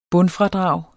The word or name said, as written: bundfradrag